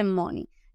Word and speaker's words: money: Y lo que hay que hacer es que el, la pronunciación de la EI ese diptongo EI, EY, pues se mezcla y se convierte en una I. ¿Ves? Money, money, money.